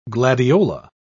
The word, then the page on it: gladiola: British English